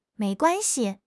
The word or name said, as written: mei2 guan1 xi5